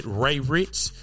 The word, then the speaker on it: Ray Rich: So if you are listening to this on any podcast streaming service, you can find me on Facebook at Ray Rich.